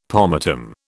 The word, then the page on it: pomatum: British English